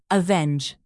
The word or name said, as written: avenge